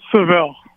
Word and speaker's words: Saville: It's Saville.